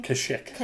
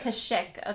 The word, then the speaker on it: Kashyyyk: But, like, maybe between every episode, you know, maybe he goes back and maybe he goes to, how do you say the planet's name? – Kashyyyk. – Kashyyyk. Okay, I wasn't quite sure.